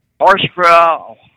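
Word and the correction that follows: Hocevar: Oh, Hosabar.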